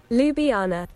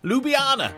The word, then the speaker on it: Ljubljana: I think it is pronounced Ljubljana. Is it Ljubljana? Can I have a pronunciation check, please? – Ljubljana. – Ljubljana.